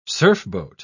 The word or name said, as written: surfboat